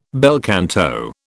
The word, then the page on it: bel canto: British English